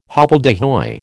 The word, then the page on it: hobbledehoy: American English